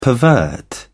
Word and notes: pervert: British English

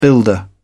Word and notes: builder: British English

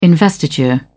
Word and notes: investiture: British English

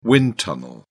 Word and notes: wind tunnel: British English

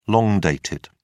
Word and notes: long-dated: British English

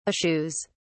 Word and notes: eschews: British English

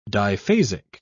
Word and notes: diphasic: British English

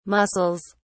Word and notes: mussels: British English

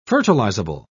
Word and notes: fertilizable: British English

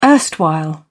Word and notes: erstwhile: British English

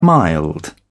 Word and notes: mild: British English

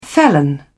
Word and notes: felon: British English

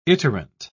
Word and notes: iterant: British English

